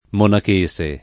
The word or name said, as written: monachese